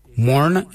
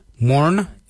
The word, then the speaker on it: moorn: Yunt is spelled Y-O-O-R-N-D. And the last colour is Morn. Morn is black. Morn is spelled M-O-O-R-N. Morn.